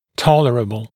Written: tolerable

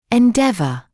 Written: endeavour

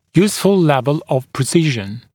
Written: useful level of precision

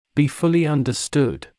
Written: be fully understood

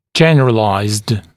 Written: generalized

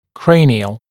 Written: cranial

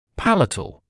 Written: palatal

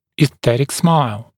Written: esthetic smile

